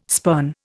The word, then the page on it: spurn: Indian